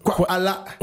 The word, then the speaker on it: Kwala: Ça se prononce Kwala, okay.